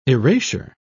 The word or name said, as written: erasure